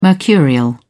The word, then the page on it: mercurial: British English